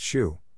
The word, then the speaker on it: SHU: Prison authorities have euphemistically dubbed it the Special Housing Unit, or SHU, pronounced SHU, for short.